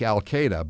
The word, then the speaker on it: Alkhader: It pronounced like Al-Qaeda, but his last name is A-L-K-H-A-D-E-R.